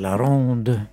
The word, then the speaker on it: La Ronde: Är det franskt uttal? – Just det, La Ronde.